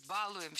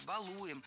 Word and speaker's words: балуем: Баловаться, баловались, балуем, все это неправильно. В нашем глаголе «баловать», «баловаться». Ударение всегда стоит за корнем, поэтому баловались, балуем. И, кстати, если мы добавим приставки к нашему слову, то ударение все равно должно быть за корнем. Побаловаться, избаловал, добалуешься и так далее.